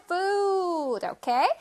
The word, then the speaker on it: food: 자, food는 발음 선생님 한번 알려줄게요. 우리 친구들 food라고 많이 하죠. food 아니고 food 우 소리를 길게 food, okay?